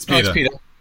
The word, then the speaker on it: PETA: It's PETA.